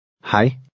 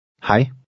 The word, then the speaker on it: hej: Hej. Hej. – The EJ combination sounds like I.